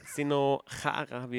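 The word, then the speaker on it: Zdeno Chara: Sino Chara, wie er ja ausgesprochen wird, ist 41 Jahre alt mittlerweile.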